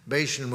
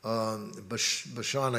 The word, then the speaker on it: Bashan: Bashan was Bashan, I guess is better pronounced.